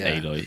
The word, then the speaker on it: Aloy: It looks like Aloy, but it's Aloy, yeah.